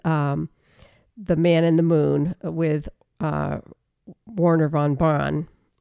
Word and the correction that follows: Werner von Braun: Werner von Braun.